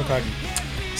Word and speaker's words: Hokage: Hokage.